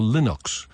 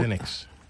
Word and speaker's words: Linux: And one of the things that is a little confusing is, it's spelled L-I-N-U-X. And is it pronounced Linux or Linux? Well, Linux. – It's according to Torvalds, who is responsible for the kernel, he calls it Linux.